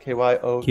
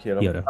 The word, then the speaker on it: Kyoto: Kyoto, is that how you say it? – Kyoto. – Yep, Kyoto.